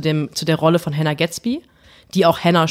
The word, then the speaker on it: Hannah Gadsby: Ich hätte es gerne geschafft bis zu Hannah Gatsby, weil Hannah Gatsby man muss es einfach so aussprechen, Hannah Gatsby ist die Frau, die uns beide zusammengebracht hat.